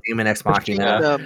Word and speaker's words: Daemon X Machina: Yeah, Daemon X Machina.